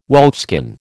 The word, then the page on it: wolfskin: American English